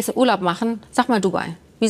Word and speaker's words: Dubai: Und der kleine Unterschied, das Richtige ist Dubai. Die Betonung.